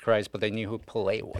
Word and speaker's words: Pelé: You say Pelé. Everyone also knows says Pelé. – Yeah. Because he says cock. Everybody says cock. – It's true. – It does have an accent on the second E.